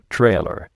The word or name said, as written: trailer